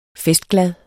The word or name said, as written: festglad